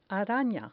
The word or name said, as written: arana